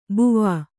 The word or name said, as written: ಬುವಾ